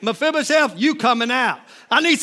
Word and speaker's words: Mephibosheth: Meth, I can't even say his name. Mephibosheth, tell him, grab him and say, come on, Mephibosheth, you coming out.